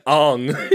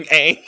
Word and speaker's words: Aang: It's not like I was saying like Ong instead of saying Aang.